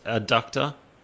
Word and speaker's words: adductor: Yeah, it's actually, it's pronounced adductor. It's not, someone's not kidnapping his groin. Trying. – Oh, yeah. A D, not a B.